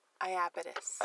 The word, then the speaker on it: Iapetus: How is this one pronounced? – Iapetus. – Iapetus.